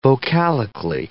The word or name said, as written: vocalically